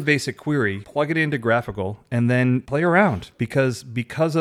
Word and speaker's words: GraphiQL: How do you pronounce it? Graphical? – Graphical. – GraphQL? – Because I've pronounced it wrong a lot. not. So straight from the source, graphical.